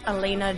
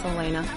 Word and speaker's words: Alana: You can find me on Twitter at Elena Jordan, A-L-A-N-A, spelled like Alana. It's Elena Jordan.